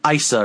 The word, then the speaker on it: Isla: It's ISLA, but it's like Issa or something. – So the L is silent.